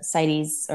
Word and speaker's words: CITES: CITES.